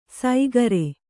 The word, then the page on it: ಸೈಗರೆ: saigare